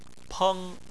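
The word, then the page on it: peng: tone 1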